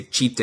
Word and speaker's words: Tite: or I guess they say Chite is how they pronounce it over there.